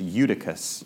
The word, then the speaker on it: Eutychus: The pronunciation is Eutychus, in case you're wondering.